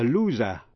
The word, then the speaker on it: loser: Và động từ to lose, L-O-S-E, động từ bất quy tắc. To lose, lost, lost, là mất. A loser, chữ S đọc là Z, kẻ thất bại.